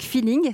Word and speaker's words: Phyling: envie de vous tourner vers cet univers avec Feeling. Ça s'écrit P-H-Y-L-I-N-G.